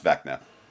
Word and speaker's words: Vecna: Vesna? – I've heard Vecna. I've heard it discussed at work. I believe it's pronounced Vecna.